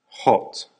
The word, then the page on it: hot: American English